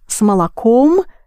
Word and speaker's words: молоком: Чай с молоком и сахаром, пожалуйста. So you have с молоком, where ом is clearly heard, it's accented, but the same ом с сахаром, but it's not accented.